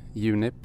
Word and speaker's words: Junip: So it's very easy to just do Junip when you're talking Swedish and Junip when you're talking English.